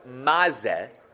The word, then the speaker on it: maze: Okay, so now, Rashi says that the words maze in the maze biyadecha are written as one. It's written mem, zayin, he. But it's pronounced ma-ze.